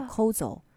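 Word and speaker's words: こうぞ: 还有最后的一个原因，是因为这些和纸其中来源之一是当地的日本的一种树，它的发音就叫 kozo。就日文发音叫 kozo 哈，它的特别的栽培和保存在日本也是一个他们花了很大力气的，因为你想要这个和纸，它的工艺发展，你的原材料你也得你也得保护嘛。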